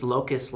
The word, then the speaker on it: Locust: It's also, it's not Locust like the bug. Sorry. It is, I just had to spell that again in my head. It's Locust like Focus, but with an L.